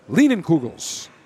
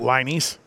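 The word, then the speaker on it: Leinenkugel's: Lienenkugels. Is that the correct pronunciation? – Correct. You can also call it Linies, right? – Linies.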